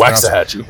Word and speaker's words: Waxahachie: Yeah, that Cal- Calvin Simpson Hunt, the, the third corner in this class from Waxahachie, I think it's how it's Waxahachie. pronounced.